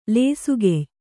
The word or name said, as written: ಲೇಸುಗೆಯ್